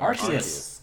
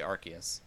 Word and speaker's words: Arceus: So it's Arceus. I've been saying Arceus. But it's actually Arceus.